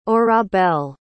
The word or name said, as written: orabel